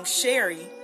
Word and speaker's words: Sherrie: Because the way my name is spelled, S H E R R I E. I was born, birthed out, and my uncle gave me my name, Sherry.